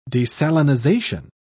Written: desalinization

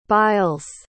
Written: biles

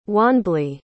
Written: wanbli

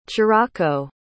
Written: cirocco

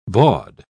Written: baud

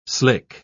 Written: slick

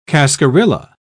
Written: cascarilla

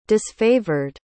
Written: disfavored

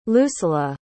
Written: lusela

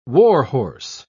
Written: warhorse

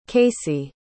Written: casey

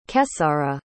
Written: kesara

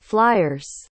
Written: fliers

